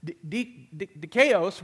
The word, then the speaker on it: dikaios: And the words that it is represented by today in our study of the New Testament is dikaios.